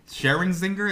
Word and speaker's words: Scherzinger: Nicole Scherzinger. – Who knows? Who knows? – Scherzinger, is that how you say it? – Yeah, I don't know.